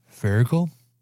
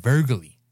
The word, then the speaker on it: Voegele: Pronunciation, V O E G E L E. So that the E after an O, or like after a vowel, you'd get the umlaut pronunciation. So Vergel, Vergel. But then you have an E at the end, Vergely. Or it could be just straight up Americanized Vogeli. – Or just Vogel.